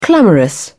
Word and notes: clamorous: British English

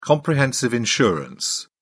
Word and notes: comprehensive insurance: British English

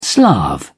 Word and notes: slav: British English

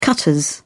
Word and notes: cutters: British English

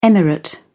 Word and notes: emirate: British English

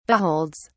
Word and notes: beholds: British English

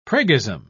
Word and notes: priggism: British English

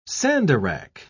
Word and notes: sandarach: British English

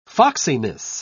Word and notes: foxiness: British English

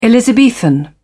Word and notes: elizabethan: British English

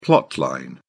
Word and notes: plot line: British English

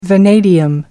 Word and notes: vanadium: American English

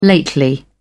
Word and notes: lately: British English

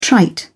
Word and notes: trite: British English

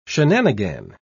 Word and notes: shenanigan: British English

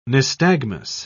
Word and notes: nystagmus: British English

